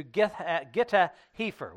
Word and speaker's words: Gath-hepher: And he gets in verse 30, he says, and from thence passeth on along on the east to Gitta-Hepher, which is another pronunciation of Gath-Hepher.